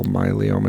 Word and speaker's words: myeloma: Multiple myeloma. Is that how you say it? – Myeloma. MYELOMA, I believe.